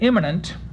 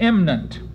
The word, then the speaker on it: imminent: The meter requires that imminent be reduced to imminent, and that the preceding preposition and article be reduced to a single unstressed syllable.